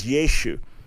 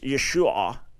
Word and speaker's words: Yeshua: Yeshu. Now, there's a linguistic argument that it originally was pronounced Yeshua, and it's an ayin at the end that's not pronounced in modern Hebrew today.